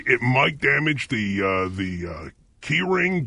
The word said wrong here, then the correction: Keurig: downspout. – Keurig. – What? – Keurig.